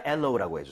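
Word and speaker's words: aloe: 하지만 엘로우라고 해주세요.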